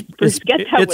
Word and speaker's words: bruschetta: So I learned I've been mispronouncing that my whole life. – Really? – It's bruschetta with a hard K.